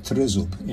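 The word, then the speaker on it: trizub (тризуб): The other symbol is the Ukrainian trident or trizub, pronounced trizub in Ukrainian.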